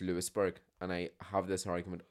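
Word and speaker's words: Louisburgh: There's a place called Lewisburg. But the Lewis is spelled as Louis. So I always call it Louisburg because it is spelled as Louis, L-O-U-I-S. But they pronounce it Lewisburg.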